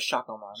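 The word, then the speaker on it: Shakkoumon: They jump into action with Sayakomon. – I thought it was Shakomon. – Shakomon. It's spelled like Sayakomon. Pronounced like Shakomon.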